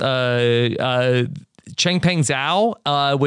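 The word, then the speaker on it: Changpeng Zhao: I believe it's pronounced Chengpeng Zhao, which they call him CZ, and SBF, a lot of acronyms.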